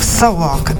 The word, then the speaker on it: Celock: You may also contact me on Telegram at the username of Celock, that is spelled C-E-L-O-C-K, or in the DJ Celock Telegram group, like what some people have done.